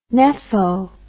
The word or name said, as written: nepho